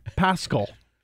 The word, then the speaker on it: Pascal: So, actually, by the way, another correct pronunciation. If you listen to the Colts, Pascal. Everybody's been calling Pascal for years now. It's Zach Pascal.